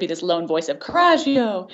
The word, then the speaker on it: coraggio: It's coraggio.